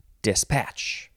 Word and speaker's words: dispatch: And in this case, you emphasize the second syllable, dispatch.